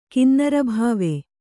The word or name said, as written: ಕಿನ್ನರಭಾವೆ